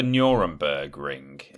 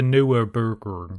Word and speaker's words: Nürburgring: It's not the Nuremberg Ring, is it? I don't know. No, of course it's not. It's the Nürburgring. – Nürburgring. – Yes. That's how you say it.